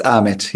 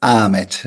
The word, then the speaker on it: Ahmet: It is Ahmet, yes. – Ahmet. – Yes. Usually in Turkey, we don't pronounce the H, so it's Ahmet.